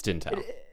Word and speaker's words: Dntel: Jimmy Tamborello is Dontell. Is that how you pronounce it? Dontell? Dintell? – It's Dintell. – It's spelled D-N-T-E-L, all capital, which is basically his work as a producer, DJ, electronic music artist.